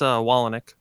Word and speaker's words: Wolyniec: It's Wolyniec.